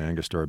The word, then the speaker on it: Angostura: You know, there's the typical, I guess, how do you say it, Angostura bidders? – Yep. Those are the only ones I know, actually, Angostura bidders.